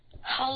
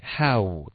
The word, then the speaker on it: how: How long was I in there? – Okay, how 的这个发音不是 how。 OK, 很多的学生都喜欢这么发，可是其实这样会出问题的，是一个 a, u, how.